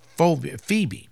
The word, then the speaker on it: Phoebe: No, that's Phoebe. I guess the O is silent.